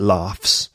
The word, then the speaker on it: laugh: By the word laugh, L-A-U-G-H, is obviously a verb. It can be a noun, to get laughs. But to laugh is the verb. Yeah, strange spelling and pronunciation. L-A-U-G-H, pronounced laugh. So it sounds like an F.